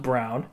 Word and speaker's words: Braun: And I also like that Christian Brown, it says Braun, pronounced Brown, respect it.